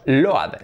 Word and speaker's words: load: Pues boat, coat, soap, jabón y load. Load, cargar algo. No digáis más load.